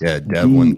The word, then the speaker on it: DEVELON: DEVELON. That's their rebrand. Pronounced Devlin. DeVelon.